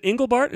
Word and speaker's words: Engelbart: Is it Engelbart? Is that how you pronounce it? – Engelbart. You did way better than my English teacher.